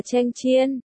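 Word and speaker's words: chênh chiên (强箭): Từ cường tiễn, đọc là chênh chiên, hài âm hoàn toàn với cưỡng gian.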